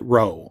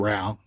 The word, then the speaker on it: Rowe: And a architect by the name of Herman, I pronounce it Rowe, R-O-W-E. Some people say it's Rao. Herman Rowe is how I pronounce it.